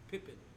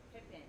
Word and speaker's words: Pippen: It's spelled P-I-P-E-N, Pippen. A P-I-P-P-E-N, Pippen. – Pippen. Okay, it's Pippen. It has to be Pippen.